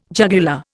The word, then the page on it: jugular: Indian